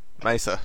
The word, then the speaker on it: Mesa: Okay, like, think of the month of May, and then Sa. Mesa. – Mesa. – I've got it. GM2K says Mesa, and then Gruntling says, I'll make a mess of you.